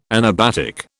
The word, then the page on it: anabatic: British English